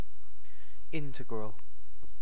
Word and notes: integral: British English

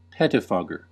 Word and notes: pettifogger: American English

